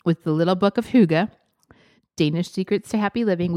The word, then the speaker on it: Hygge: We think that's how you say it. We looked it up. – We did just watch a YouTube video. – I think we're saying it correctly, but it is spelled H-Y-G-G-E.